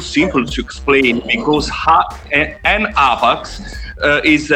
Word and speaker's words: HAPAX: And by the way, it is spelled H-A-P-A-X. I do occasionally accidentally call it HAPAX, but it's HAPAX, right? – Yeah.